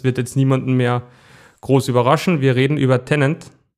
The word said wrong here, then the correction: Tenet: habe ich es richtig gesagt? – Na. – Wie heißt es richtig? – Tenet. Tenet.